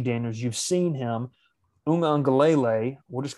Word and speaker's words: Uiagalelei: Is that how you say it? I thought it was yeah. Ugalele. – It's it's Uiagalelei, and I know that only because I watched a YouTube video on how to pronounce it a little bit earlier.